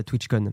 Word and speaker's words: TwitchCon: À ne pas prononcer en français la TwitchCon, bien sûr. Hein, oui.